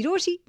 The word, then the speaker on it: Rochie: मेरा नाम है मिर्ची रोशी, नाम की स्पेलिंग है ROCHIE एंड मेरे साथ लाइन पर रितेश है।